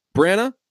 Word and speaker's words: Branagh: Branagh? – Branagh. – Eric Bana. and yep, that's it. Jeremy Renner, Miles Teller, Eric Bana, and James Franco. – I have James Franco here. – I have Kenneth Branagh.